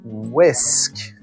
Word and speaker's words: whisk: それは、ウィスク。はい、そうですね。 risk, risk. でも、 w-h-i-s-k。そうだね。 h は発音しないかな。